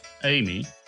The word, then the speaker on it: Emmy: Amy, spelt E-M-M-Y, but pronounced Amy, is gorgeous with a special feminine grace in her movements.